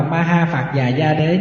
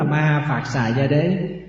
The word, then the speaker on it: ma ha phạt xà gia đế: Thì có chỗ đọc là ma ha phạt già gia đế, nhưng mà đúng là ma ha phạt xà gia đế.